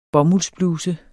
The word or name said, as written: bomuldsbluse